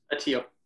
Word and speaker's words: Attia: Speaking of folks who've done like rural organizing and rural environmental organizing, during the fires, environmental organizer champion, really, and I may mispronounce his last name, George Attia, who was Attia. – Mm-hmm. Attia, my bad.